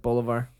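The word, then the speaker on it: bolívar: Am I saying that right, bolivar? – I believe so.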